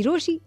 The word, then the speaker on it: Rochie: मेरा नाम है मिर्ची रोशी, नाम की स्पेलिंग है ROCHIE.